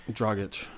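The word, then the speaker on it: Dragić: And, like, you know, again, I think he'll work better with Jess Dragic. How do you say his name? – Dragic. – Thank you.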